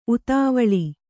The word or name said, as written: ಉತಾವಿಳ